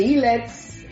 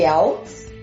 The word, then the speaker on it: IELTS: Tem aluno que chama de ILETS. – ILETS. mas o correto, a pronúncia correta é IELTS, né? Então o, o, o stress da, da palavra está no E e TOEFL.